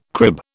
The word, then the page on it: crib: British English